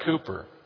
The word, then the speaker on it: Cowper: His name is spelled in our hymn book, C-O-W-P-E-R, but he was from England and they pronounced that Cooper.